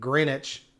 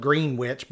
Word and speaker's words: Greenwich: And then in 1925, his family moves to Greenwich. It's spelled Greenwich, but I think they say it Greenwich, Connecticut.